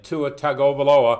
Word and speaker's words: Tua Tagovailoa: And Tua Tagovailoa, how about that, John? I got the name pronounced right.